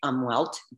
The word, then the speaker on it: Umwelt: Well, Americans say it umwelt, U-M-W-E-L-T. It's developed by a German scientist, so probably pronounced umwelt.